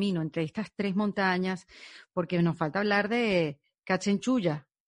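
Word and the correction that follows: Kangchenjunga: Canchenchunga, sí.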